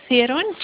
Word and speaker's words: Theron: It's M-E-N-I-E. And then Theron, T-H-E-R-O-N. It's M-B, then the Theron, T-H-E-R-O-N.